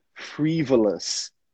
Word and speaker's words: frivolous: Frivolous. Frivolous. Algo sem utilidade, algo bobo. – Frivolous. Repete aí para eu checar a sua pronúncia. Frivolous. Perfect. Frivolous.